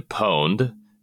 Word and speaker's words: Pwned: So there's a service called Have I Been Pwned? And whenever you hear pwned, it's spelled P as in person, W-N-E-D. So like the word owned, but starting with a P.